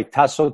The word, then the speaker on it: Taso: Yes, the name is Anastasios or Tasso. I go by Tasso, T-A-S-O.